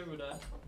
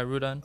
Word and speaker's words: Rudon: You can say Rudon. – All right. – If you roll the tongue, that's cool. – So what is it, Rudon? – Yeah, Rudon.